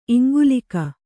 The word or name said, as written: ಇಂಗಲೀಕ